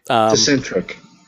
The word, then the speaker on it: Tecentriq: Anyway, it's sold under the brand name Tecentriq. – Tecentriq? Yeah. – Tecentriq.